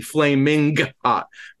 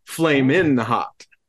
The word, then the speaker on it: Flamin' Hot: but I caught the hard G there, the Flaming Hot, but it's Flamin Hot.